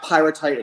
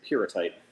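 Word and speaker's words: pyrrhotite: It's not pyrotite, it's pyrotite.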